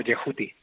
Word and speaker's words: Yehuti: Bueno, correctamente nadie lo sabe, pero es más o menos algo así como Yehuti. – Yehuti. – ¿No? Porque los egipcios escribían las consonantes, pero no las vocales. Entonces la pronunciación exacta pues es un poco difícil.